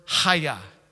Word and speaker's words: Chaya: In the Hebrew, this word I am is the word Chaya. Chaya. I'm gonna say Chaya, Chaya. Not like Chaya, like Chaya. Like it literally, that's how you pronounce it. Yeah, like a karate chop.